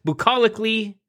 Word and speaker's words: bucolically: I'm your host, Vincent Franchini from Tumblr House, here with a bulacally colicky Charles Coulomb. Did I say that right? Bucolically colicky Charles Coulomb. There we go.